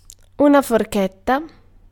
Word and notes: fork: American English; [fɔɹk]

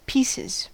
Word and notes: pieces: American English

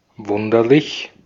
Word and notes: strange: ['streɪn.dʒ]

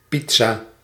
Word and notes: pizza: /ˈpit.sa/; /ˈpid.za/

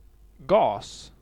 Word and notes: gas: [gɑːs]

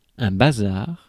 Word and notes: things: /θɪŋz/

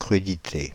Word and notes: crudités: /kʁy.di.te/; France (Île-de-France)

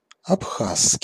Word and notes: абхазский: /ɐpˈxasːkʲɪj/

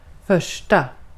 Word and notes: första: /ˈfœʂʈa/

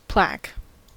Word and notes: plaque: /plæk/; [plæk]

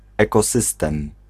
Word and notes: ecosysteem: /ˈeːkoːsisteːm/

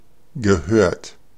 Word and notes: gehört: /ɡəˈhøːɐ̯t/

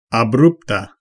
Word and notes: brusque: /bʁysk/; France

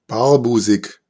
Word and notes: barbusig: /ˈbaːɐ̯ˌbuːzɪç/

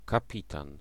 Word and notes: skipper: American English; /ˈskɪpɚ/

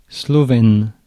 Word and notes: slovène: France; [slɔ.vɛn(ə)]

